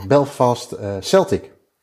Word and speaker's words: Belfast Celtic: Eh, die zaterdag begonnen we, zoals je net al aangaf in het fragment, eh, over, over Belfast Celtic. zeg ik dat goed? Ja.